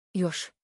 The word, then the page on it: пунтулчэ: [пунтулчэ]; [puntulche]